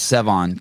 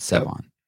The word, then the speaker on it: Sevan: My name's Sevon, kind of like seven, but Sevon.